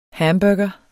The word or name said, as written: hamburger